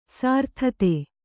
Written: ಸರತಾತಿ

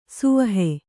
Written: ಸವಿಹ

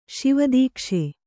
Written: ಶಿವದೀಕ್ಷೆ

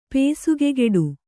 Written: ಪಸುಗೆಗೆಡು

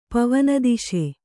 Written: ಪವನದಿಶೆ

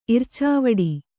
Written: ಇರ್ಚಾವಡಿ

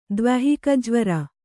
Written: ದ್ವ್ಯಾಹಿಕಜ್ವರ

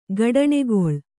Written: ಗಡಣಗೊಳ್ಳು

